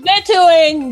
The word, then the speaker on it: vetoing: I'm vetoing this. – What was that word? – Vetoing. – Veto. – Whatever. – You put the emphasis on the wrong syllable there.